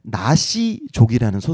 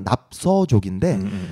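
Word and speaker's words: 纳西族: 그리고 그곳이 다 중국 소수민족 중에서 나시족이라는 소수민족이 있어요. 한국 발음 납서족인데 동파 문자를 사용하는 분들이에요.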